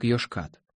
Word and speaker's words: Йошкат: Произносится как Йошкат.